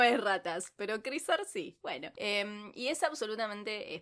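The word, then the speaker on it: Krysař: La primera es Crisar. Se escribe K-R-Y-S-A-R.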